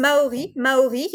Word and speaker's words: Maori: No, you're right. That's how it's pronounced. Maori.